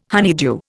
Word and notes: honeydew: Indian

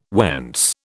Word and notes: whence: British English

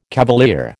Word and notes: cavalier: American English

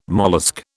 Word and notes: mollusc: British English

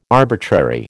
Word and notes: arbitrary: American English

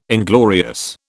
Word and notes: inglorious: British English

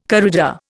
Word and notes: corrida: Indian

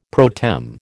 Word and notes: pro tem: American English

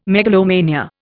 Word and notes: megalomania: Indian

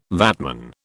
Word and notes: VATman: British English

